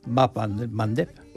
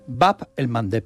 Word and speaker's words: Bab el Mandeb: Bab el Mandeb. – Bab el Mandeb, esa me la voy a apuntar. – Cuesta un poquito pronunciarlo, por Mandeb. – Eso en Occidente se dice el Mandeb.